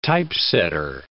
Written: typesetter

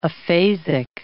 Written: aphasic

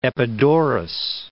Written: Epidaurus

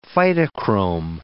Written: phytochrome

